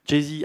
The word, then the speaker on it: Jay-Z: Jay-Z, ah oui c'est ça, voilà, je suis un peu...